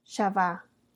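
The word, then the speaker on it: Shavá (חַוָּה): El nombre de Eva en hebreo se pronuncia Shavá, que significa, en inglés significa to live, en español eh, el verbo vivir o dador de vida.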